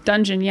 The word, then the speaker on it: Dungjen: Dungjen, yeah, like yes. And dragons.